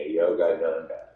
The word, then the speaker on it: Yogananda: P-A-R-A-M-A-H-A-N-S-A, Paramahansa Yoga. Yoganda, Yogananda.